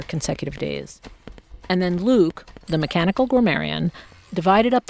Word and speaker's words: LIWC: L-I-W-C. – Linguistic inquiry and word count. – Which I pronounce Luke.